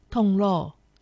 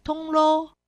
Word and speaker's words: 铜锣: 客家话是县腔发音“ 铜锣”，海陆腔发音“ 铜锣”。